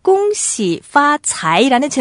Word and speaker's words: 恭喜发财: 중국어 발음으로 恭喜发财, 恭喜发财.